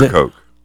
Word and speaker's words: Ocracoke: Some of my church members had scheduled a week starting this week to, I think they call it Okeedoke Island in North Carolina. If I'm pronouncing it. Ocracoke. – What's it called again? – Ocracoke. – Ocracoke, yeah.